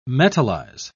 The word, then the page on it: metallize: British English